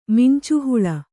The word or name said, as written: ಮಿಂಚುಹುಳು